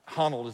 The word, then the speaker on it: Honnold: Alex Honnold is how you say his name.